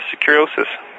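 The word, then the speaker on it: Securosis: Yeah, you got it pretty close, Jeff. It's Securiosis.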